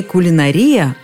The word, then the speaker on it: кулинария: В первоисточнике ударение приходилось на «а», и это сохранялось в русском слове «кулинария». Точнее, в одном из его вариантов, который считался, безусловно, литературным. Однако нельзя было не признать очевидного. Все вокруг произносили «кулинария».